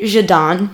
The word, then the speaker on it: Jaden: Well, it's spelled Jaden, but you say it Jadon.